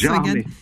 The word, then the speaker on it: Gérardmer: Gérardmer.